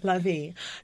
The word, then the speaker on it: Lavie: So, Lavie. – Yes. – I'm saying it right, right? – Yes, Lavie. – Okay. Lavie.